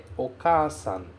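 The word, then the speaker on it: okasan (お母さん): E a pronúncia dele é okasan. Okasan, que quer dizer mãe.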